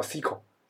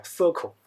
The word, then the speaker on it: SQL: 国外的呢，他们老师教的的时候呢，都会给他们叫叫叫 SQL，然后呢，国内的可能有一帮老师呢，一开始都是讲讲 Circle，才会有这个分别。